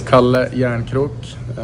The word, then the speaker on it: Kalle Järnkrok: The one I want is Cali Yarncroc. How are we saying Cali Yarncroc? See if we can find that one on command. – My name is Kalle Järnkrok, and yeah, that's how we pronounce it.